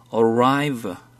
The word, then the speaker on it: arrive: Arrive. – Arrive. – V예요, V. Arrive. – Arrive. Arrive. – Arrive. – Arrive. – V 발음 어떻게 한다고? – V. 아랫입술을 깨물었다가 피듯이.